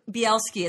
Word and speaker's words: Bielski: Bielski. It's like a okay. BLT, but Bielski.